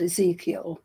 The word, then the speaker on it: Ezekiel: Isaacal, is that how you pronounce his name? Isaacal, didn't he? In the Bible, see the flying saucer. Am I correct or am I not? – I have channeled Ezekiel.